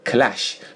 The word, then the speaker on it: clash: 啊，你不能说伴随着一阵刺耳的撞击声不好听啊，就是咣当一声啊，clash with a clash 啊，前面是 crash 一个 r，这要是一个 l clash，注意发音，好吧？